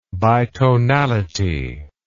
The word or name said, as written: bitonality